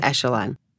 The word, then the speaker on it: echelon: I realized that I have been mispronouncing the word echelon. I see, I can't even remember. Is it echelon? It's echelon.